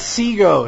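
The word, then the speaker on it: Xsigo: Like, I guess someone like Exego could probably do something similar. – Oh, it's Sego. It's Sego. It's not Exego. The X is silent.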